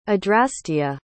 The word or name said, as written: adrastea